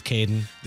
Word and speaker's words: Kadon: Now, how would you pronounce this one? K-A-D-O-N. Is that a Caden without but missing a Y or any kind of context? – Yeah, I think it's Caden.